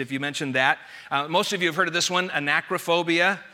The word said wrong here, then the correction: arachnophobia: arachnophobia, let me say that correctly, that's the fear of spiders.